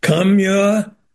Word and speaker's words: Kamala: By the way, to pronounce, I don't know why we mispronounce Kamala. Kamala. And communist. The first syllable is the same.